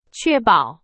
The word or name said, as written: 确保